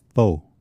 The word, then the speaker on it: faux: You know, they talk about in the Bible um, in uh, Romans, don't they talk about the the Fox Gospel? – Yes. Good job. – My apologies to the French speaking people out there. It's pronounced faux.